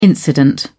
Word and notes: incident: British English

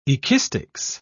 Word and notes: ekistics: British English

